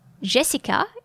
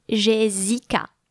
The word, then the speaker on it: Jessica: And that is also why my name, Jessica, is spelled with double S, because before and after the S, there is the E and the I, which are vowels, so with only one S, you would read Jessica. But Jessica needs to be spelt with two S's.